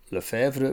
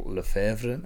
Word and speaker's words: Lefebvre: To solve the hassle, my real name Jean-Paul Lefebvre brings about international, because it helps my Chinese and American clients and friends in pronouncing and understanding my name.